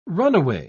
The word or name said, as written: runaway